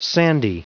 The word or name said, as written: sandy